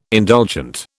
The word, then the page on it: indulgent: British English